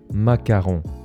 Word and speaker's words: macaron: Aujourd'hui, on va parler du macaron。这应该是很多人都知道的一个甜点啊，叫做马卡龙。它的发音跟法国总统马克宏哎不一样哦。法国总统是 Macron，全名是 Emmanuel Macron。那马卡龙是 macaron，差一个字母而已哦，但念起来哎真的是很不一样哦。